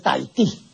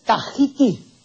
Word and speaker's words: Tahiti: Eh, estampa dos errores, porque no es tahitiano ni tampoco es Tahití. la pronunciación correcta en el nombre de esta isla es Tahiti.